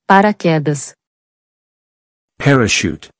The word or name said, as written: Para quedas